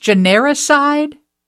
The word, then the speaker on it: genericide: No, the word actually, in your article, is it pronounced genericide? – Yeah, it's just like suicide, except it's genericide.